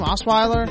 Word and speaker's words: Osweiler: I think Osweiler, how do you say his name? Osweiler? – Yeah.